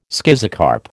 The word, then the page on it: schizocarp: American English